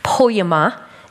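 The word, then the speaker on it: poema (ποίημα): So the word in this passage, workmanship, it's a little Greek word, poema, is how you pronounce it.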